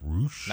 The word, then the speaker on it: Rush: Spelled R-U-S-H, but it's pronounced Roosh.